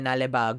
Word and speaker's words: Naleba: Naleba. That I definitely know that it's pronounced like that.